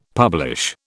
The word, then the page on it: publish: British English